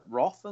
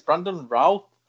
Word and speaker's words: Brandon Routh: And Brandon Routh called Roth and stuff, and Brandon Ruth. And it's like, no, it's Brandon Routh.